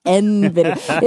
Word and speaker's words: NVIDIA: Somebody needs to tell Kara that it's pronounced NVIDIA.